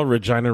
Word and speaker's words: Regina: First of all, Regina rhymes with fun.